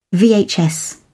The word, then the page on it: vhs: British English